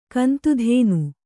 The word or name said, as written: ಕಂತುಧೇನು